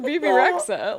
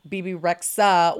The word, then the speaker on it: Rexha: It's just Bebe Rexha. I think it's like a silent H.